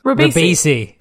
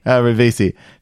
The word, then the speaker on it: Ribisi: Ribisi. – Ribisi.